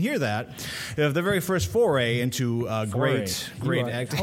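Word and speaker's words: Fauré: I like foray. – Thank you. Thank you. – Well, thank you. – She said it correctly.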